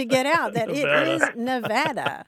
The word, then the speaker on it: Nevada: I grew up in Kentucky, and I have to confess that I pronounced it like your friends in North Carolina, Nevada. – Right. – I never knew anything different until just a few years ago, and now I'm training myself to say Nevada because I've run into so many people from that state who are very, very insistent that it's a short A.